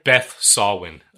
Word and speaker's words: Beth Sawin: Beth Solwin. – Okay. – Elizabeth Solwin. – How do you spell that? – S-A-W-I-N.